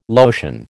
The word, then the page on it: lotion: American English